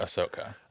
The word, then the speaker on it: Ahsoka: You don't say the H. You say, it's not Ahsoka. No. It's Ahsoka. – Ahsoka.